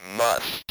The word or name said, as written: must